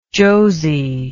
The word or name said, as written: josie